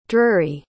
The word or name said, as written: drury